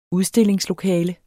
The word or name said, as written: udstillingslokale